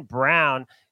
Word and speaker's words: Braun: And I will also like a player prop, Christian Brown, spelled Braun, pronounced Brown, over 12.5 points at plus money, because Leaky Black is going to be on Agbaji.